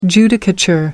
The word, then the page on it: judicature: American English